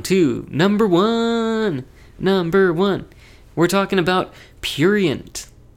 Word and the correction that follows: Prurient: Or sorry, prurient. I can't ever say that right.